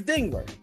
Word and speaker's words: Dengler: No, it's Dengler.